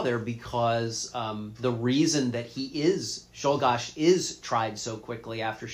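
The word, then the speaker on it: Czolgosz: Zolgosh. – Yes. – Or Zolgosh. I've heard it pronounced both ways, He's quickly tried, executed, it's over.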